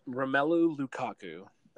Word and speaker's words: Romelu Lukaku: First off, it was funny because I was watching Belgium's last game, and it's Lukaku, right? That's how you say it. – Yep, Romelu Lukaku.